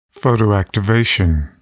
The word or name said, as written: photoactivation